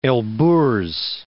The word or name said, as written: Elburz Mountains